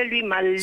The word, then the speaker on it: Louis Malle: Se pronuncia Louis Malle. – Sí, Malle, sí, dale.